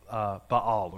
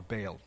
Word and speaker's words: Baal: something like the wife of Baal or Baal, depending on your pronunciation.